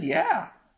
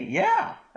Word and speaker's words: yea: And he said, yeah. Y-E-A? Yeah. That's how you pronounce that. People think it's yea.